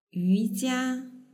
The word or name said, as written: yujia